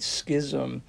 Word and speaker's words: Schism: In 1054 AD, we have something known as the Great Schism, which is a period in church history when the Catholic Church broke off of the Orthodox Church, and I did say that correctly.